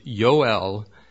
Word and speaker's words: Yoel: The prophet Joel, or Joel, or the Hebrew pronunciation with a Y sound, Yoel, means Jehovah is God, or a closer rendering of the Hebrew, whose God is Jehovah.